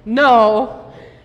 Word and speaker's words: know: They are know, know, and go. I heard the silent K that time.